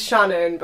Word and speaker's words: Shannon: It's like, is it Sham? Shan? – It's Shannon, but I just prefer Sham.